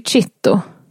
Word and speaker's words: citto: Och Condon tycker sig höra någon utbrista "Statti citto", alltså "håll käften" på italienska. – Mm-hmm. – Eller "statti citto", jag vet inte hur man uttalar det. – Citto tror jag, det är så med zäta.